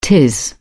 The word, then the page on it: tis: British English